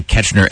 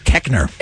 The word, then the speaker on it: Koechner: Ketchner, and I guess he got a little pissy about it. So we're going to Keckner.